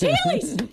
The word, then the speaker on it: Heelys: Is it wheelies? – It's Heelys.